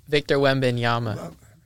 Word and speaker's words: Victor Wembanyama: Oh, Victor Wembanyama.